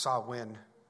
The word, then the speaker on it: Samhain: It's actually pronounced Samhain.